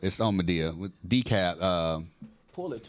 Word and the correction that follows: DeKalb: D e k a l b. That's not. That's not what it is. – DeKalb.